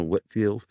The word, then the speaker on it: Whitefield: When they hear about George Whitefield, he is told, no, Whitfield. that will come up, Brian. But what else would you like to say about that, Pastor Kurt? – Well, yes, Whitfield had a long life, well, really, a lifetime of friendship with John and Charles Wesley.